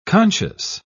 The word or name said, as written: conscious